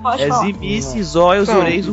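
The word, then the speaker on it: Tzimisce: Eu sou jogo de Sminci, tá? – Zimice. Esse aí tem a pronúncia no livro, terceira edição realizada. É Zimice. – Zimice? – Esquece o T. Esquece o T, Zimice.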